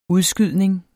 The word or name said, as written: udskydning